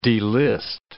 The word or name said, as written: delist